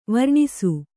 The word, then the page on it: ವಾರಾಣಸಿ: vārāṇasi